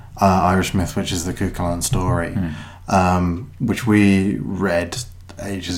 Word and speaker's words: Cú Chulainn: It's based on the Cú Chulainn myth. Which is an Irish myth, or it's Cú Chulainn as well, because when you're in the north, you soften the second syllable on anything.